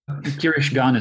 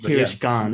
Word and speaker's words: Kirishgan: And I believe the guy's name is, I'm going to try and pronounce it, Kirishgan? – Kirishgan is how I think of it. – Kirishgan, yeah. Perfect.